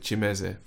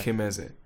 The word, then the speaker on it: Kimeze: We pronounce it Chimeze, but it looks right. Like Kimeze.